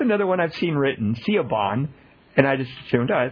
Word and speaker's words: Siobhan: S-I-O-B-A-H-N. – I don't know how to pronounce that one, but B-H-A-N, I would pronounce Siobhan. – Yeah, B-H-A-N, I don't know what I said. Say it again. – Siobhan.